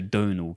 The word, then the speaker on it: Domhnall: It's spelt like Domhnall Gleeson, but it's actually like... he said it's like tonal, but with a D. So Donal Gleeson.